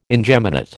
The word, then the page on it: ingeminate: American English